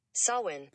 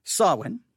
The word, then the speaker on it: Samhain: Samhain. – Samhain. All right. Samhain. Now, again, it's spelled S-A-M-H-A-I-N. Samhain. Now, why am I giving you the word Samhain?